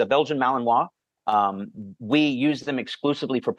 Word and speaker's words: Malinois: They're like, I have a Belgian Malinois.